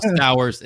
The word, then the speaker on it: Stowers: Now, the one we alluded to a little bit earlier that rhymes with Colton Cowser, kind of, is Kyle Stowers. It doesn't really rhyme now that I say it out loud, but it's fine. It's got the same vowel sound.